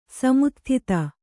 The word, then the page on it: ಸಮುತ್ಥಿತ: samutthita